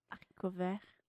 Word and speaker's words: haricot vert: Haricot vert.